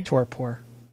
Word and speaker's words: torpor: They staked him, so he was in Topor. Topor. How's that word pronounced? I'm sorry. – Torpor. But the way you said it would have been like super like, I'm struggling with the English because I'm Welsh.